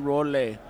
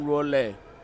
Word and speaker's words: Rolle: Is that how you say it, John Rolle? Yeah, man, that's how you say it, man, Rolle, John Rolle.